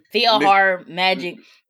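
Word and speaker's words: PhilharMagic: I want to say that, but that Mickey's Filer Magic, how do you pronounce Mickey's it? – Portion? It's like Philhar Magic. It's Philhar. For those, Mickey's, it's, I fell asleep.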